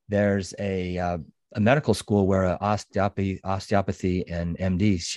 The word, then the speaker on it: osteopathy: osteopathy.